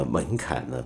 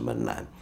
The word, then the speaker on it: 门槛: 那么最近有朋友说我昨天的节目呢，哪吒呢被我读成了那吒，然后跟着门槛呢，我总是把它读成门栏，这是怎么回事呢？